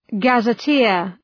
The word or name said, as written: gazetteer